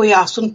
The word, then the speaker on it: Jáson: a taky se nevyslovuje jako s tím hebrejským způsobem, ale vyslovuje se jako Jásun, píše se I-O, jo, jako tím, tou výslovností tak, jak je, tak to I-O se čte jako já, tak jako se třeba v Byzancii a podobně tímto způsobem se mluvilo, to jsou poloslovanský, slovanský řeči.